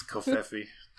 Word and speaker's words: Covfefe: That's how you say it. It's Covfefe.